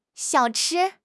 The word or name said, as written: xiao3 chi1